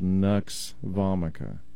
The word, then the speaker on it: Nux Vomica: It's spelled N-U-X, first word. Second word is vomica. Sounds almost like vomit, but it's like V-O-M-I-C-A.